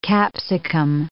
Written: capsicum

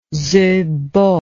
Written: Zibo

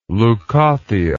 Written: leucothea

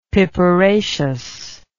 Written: piperaceous

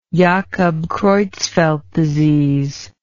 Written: jakob creutzfeldt disease

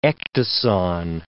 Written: ecdyson

